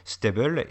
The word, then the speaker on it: STABEL: Il y a le fait que le programme de reconnaissance faciale que, pitch William Defoe au début du film s'appelle Stable, c'est un acronyme S-T-A-B-E-L, ça se prononce, il le prononce, en tout cas lui, Stable,